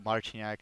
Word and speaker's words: Marciniak: That will be at TJ Marciniak, and it's spelled TJMARCINIAK.